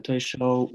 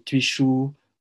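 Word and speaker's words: tui shou: Donc Toisho, ou certains le prononcent aussi Tuichu en français, on traduit ça par la poussée des mains.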